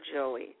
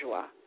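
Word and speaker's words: Joie Lee: Well, you know, her family called her Joey. She changed the pronunciation to Joie when she started working. – Okay. – Yeah, it looks like Joey on paper, but it's pronounced the French pronunciation Joie.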